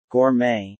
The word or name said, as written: Gourmet